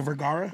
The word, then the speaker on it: Vergara: Is that how you say it, Vergara? – Yes.